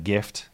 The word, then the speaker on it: GIF: That's my argument, is that the word for which the first letter stands is a hard G, therefore I go with hard G. But that's not the only one. Again, if you just looked at that sequence of letters and how you'd pronounce it, you'd say it looks like gift, it looks like give. That is GIF.